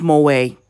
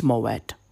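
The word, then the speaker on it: Moët: Is it Moet or is it Moe? It's Moet.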